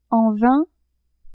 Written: en vain

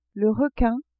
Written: requin

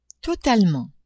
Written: totalement

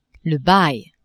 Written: bail

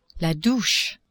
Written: douche

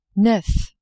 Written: neuf

